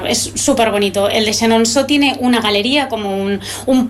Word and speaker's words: Chenonceau: Chenonceau, muy bien, muy bien a la pronunciación, ¿eh? – Bueno, genial, sí.